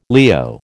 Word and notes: Leo: American English

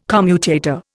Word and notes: commutator: Indian

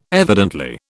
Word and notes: evidently: British English

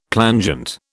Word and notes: plangent: British English

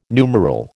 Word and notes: numeral: American English

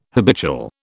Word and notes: habitual: British English

